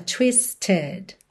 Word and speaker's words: twisted: Aqui, olha, nós temos um E que é pronunciado, twisted, ok? Então, twisted and tied.